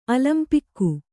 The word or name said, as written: ಅಲಂಪಿಕ್ಕು